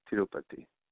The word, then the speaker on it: Tirupati: Tirupati. Tirupati.